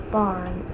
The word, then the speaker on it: Barnes: Okay, Karen, K-A-R-E-N, maiden name is Sebree, S-E-B-R-E-E, and last name is Barnes, B-A-R-N-E-S.